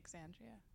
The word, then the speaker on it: Xandria: You pronounce the X, so it's Xandria.